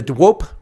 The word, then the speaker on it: De Hoop: And penguins have been released into the wild at the Dwop Reserve. That's right, folks. Yeah, hopefully I pronounce it correctly in Afrikaans. It's spelled hope, but it's not hoop. It's Dwop. Dwop Reserve.